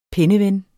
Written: penneven

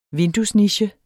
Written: vinduesniche

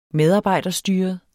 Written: medarbejderstyret

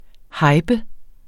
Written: hyper

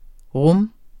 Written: rum

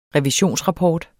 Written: revisionsrapport

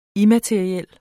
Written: immateriel

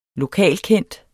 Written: LOKALKENDT

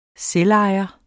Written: selvejer

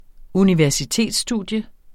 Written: universitetsstudium

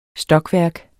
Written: stokværk

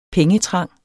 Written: pengetrang

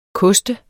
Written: koster

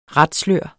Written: ratslør

